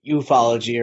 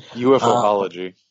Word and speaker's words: ufology: The schools of thought for ufology or ufology, the study of UFOs. – UFOology. – Yes, that's the most correct way to say that.